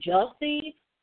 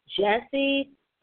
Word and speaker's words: Jussie: Jelsey? Is it Jelsey? Jussie Smollett, Smollett.